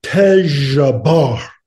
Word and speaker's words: Tezjbar: And he said, no, Tejbar. And I said, spell it. And he took my hand and said, T-E-Z-J-B-A-R, Tejbar.